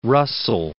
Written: Russell

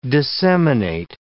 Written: disseminate

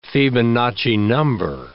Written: Fibonacci number

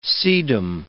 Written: sedum

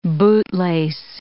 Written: bootlace

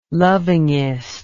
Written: lovingest

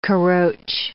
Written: caroche

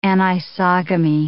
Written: anisogamy

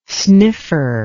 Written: sniffer

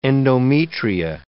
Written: endometria

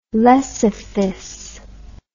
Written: lecythis

